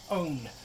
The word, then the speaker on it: pwn: This next one is really weird because it's spelled P-W-N, but it's pronounced like own.